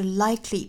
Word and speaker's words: likely: 需要补充的一点呢，就是 likely 这个词和我们昨天讲到的 exactly 和 perfectly 其实是一样的道理。我们发现其实都是 ly 这样的发音，所以我们读的时候一定要注意在 k 的后面不要拖音哈，不是 likely 而是 likely, cli, cli, likely 这个词也要注意是两音节